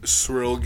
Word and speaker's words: Ciryl: I mean, couldn't pronounce that worse, but I think I'm gonna go with surreal Gane. Ciryl Gane. – Surreal, Gane. A surreal gain.